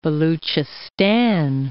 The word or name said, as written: Baluchistan